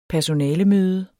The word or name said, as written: personalemøde